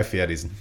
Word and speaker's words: Fieri: And Guy Fieri's not that bad of a host. – Don't say his name like that. – That's how it's pronounced. – It's Fieri.